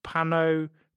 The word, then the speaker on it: Panopoulos: The name hailed from the brand of canned fruit that Chatham Ontario restaurant owner Sam Populomonopoulos placed on a standard tomato and cheese pie, quote, just for fun, alongside bits of ham. I should pronounce his name properly. It's Panopoulos. Panopoulos. He's Greek.